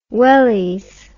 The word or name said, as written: wellies